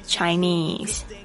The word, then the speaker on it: Chinese: 那还有一个部分就是不是 China，而是 China，所以嘴巴稍微嘟一下，China China，而不是 China。不是 China，不是掐，不是给你掐了哦。哦，好，所以呢，大家注意一下这个发音是 Chinese。